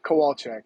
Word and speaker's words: Kowalczyk: and Evan Kowalczyk. Did I say that correctly, Evan? – Uh, Kowalczyk, yeah.